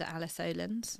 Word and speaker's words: Alice Olins: They can find me on LinkedIn under Alice Olins.